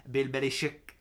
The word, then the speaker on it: Belichick: I'm going to pronounce it the proper Croatian way. Bill Belichick. I was expecting a little more sauce from the big guy.